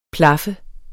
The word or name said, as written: plaf